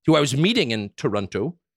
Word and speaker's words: Toronto: Toronto? – Yeah.